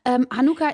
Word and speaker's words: Hanukkah: So spricht man das nämlich aus, wusste ich vorher auch noch nicht. Hanukkah ist ja das jüdische Lichterfest und da gibt es am Sonntag einen kreativen Familienworkshop.